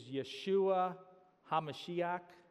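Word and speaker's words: Yeshua HaMashiach (ישוע המשיח): The Hebrew pronunciation for Jesus is Yeshua HaMashiach, Jesus the Messiah.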